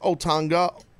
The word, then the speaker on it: Otunga: by adding, and this is, it sounds so disrespectful, but adding people like Otunga, or am I saying his name right? – I think that's how it's pronounced.